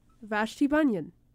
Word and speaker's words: Vashti Bunyan: The next song, I had to look up the pronunciation of her name to make sure I wasn't saying it wrong. – Leve. – This is, no. I'd Like to Walk Around in Your Mind by Vashti Bunyan. Vashti Bunyan. That's how you say it.